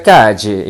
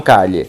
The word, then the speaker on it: calle: Não se pronuncia, é, se pronuncia cade e não calhe. Cade, cade, cade. Não calhe, como nós é, costumeiramente é, aprendemos a falar os dois L's como se fosse um LH.